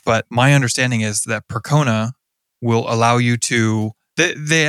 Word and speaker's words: Percona: One was a product called Percona. I believe I'm pronouncing that correctly. Mm-hmm. P-E-R-C-O-N-A.